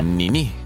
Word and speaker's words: Nini: Da ist immer die Betonung auf der letzten Silbe. – Nini.